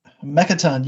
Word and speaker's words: Mechaton: Mechaton, you got it. – Got it. Mechaton, like that, not like Mechaton. Mechaton, yeah. one syllable or two. – One, yeah. – Two yeah. – One fast word.